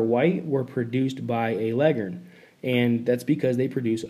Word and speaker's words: Leghorn: It's spelled L-E-G-H-O-R-N, Leghorn, but everybody knows that it's pronounced Leghorn.